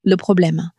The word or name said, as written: probleme